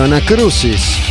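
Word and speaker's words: Anacrusis: Repito para que anoten, se escribe así literalmente como pronuncio, Anacrucis.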